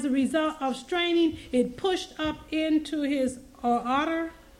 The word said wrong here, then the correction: aorta: How do you say that? – Aorta.